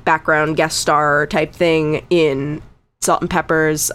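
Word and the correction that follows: Pepa: or Peppa. It's spelled like that, but Peppa.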